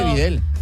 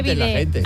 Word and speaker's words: bidé: Que no se dice bidel. – Que no se dice bidé, se dice bidé.